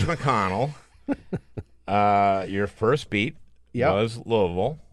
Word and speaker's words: Louisville: It is Louisville, and the way you say it, if you're from Louisville, is to collapse it all into one melted syllable, Louisville.